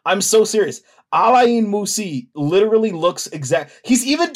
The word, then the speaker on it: Alain Moussi: It's A L A I N M O U S S I. Alain Moussi.